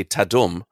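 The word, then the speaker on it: Tudum: It's written T-A-D-U-M, which when I read it, I would say Tadum. But internally, it's pronounced Tadum.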